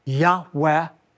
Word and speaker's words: Yahweh: Most scholars believe it's pronounced a reverent, breathy Yahweh.